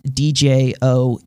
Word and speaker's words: DJONDO: It's called John Doe, but it's spelled funky. It's all one word, all capital letters, DJONDO. So it looks like John Doe with a silent D at the beginning, basically.